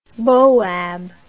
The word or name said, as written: boab